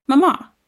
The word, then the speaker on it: mamá: Mamá. Mom? Say it. Mamá. The accent is on the last syllable.